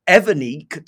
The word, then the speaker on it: Evernique: I think they're called Evernique, to kind of rhyme with my name, Dominic.